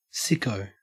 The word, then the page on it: sicko: Australian English